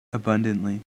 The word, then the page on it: abundantly: American English